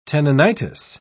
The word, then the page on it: tenonitis: British English